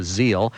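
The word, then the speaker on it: ZiL: Those great big Zills. – Is it Zills? – That's what we're told. It might be pronounced Zeal. What is it? Zeal. Evelyn tells us it's a Zeal.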